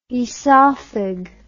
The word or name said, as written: oesophag